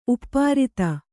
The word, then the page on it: ಉಪ್ಪಾರಿತ: uppārita